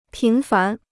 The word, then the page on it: 频繁: pín fán